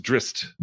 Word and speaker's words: Drizzt: Apparently it's pronounced drist, which I think is stupid. I think I still say drizzard.